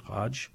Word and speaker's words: Haj: The artistic director, I think his name, first name is Joseph Hodge. I don't know if I'm pronouncing that right. H-A-J. Is that right, Hodge? – Yeah, it sounds right now.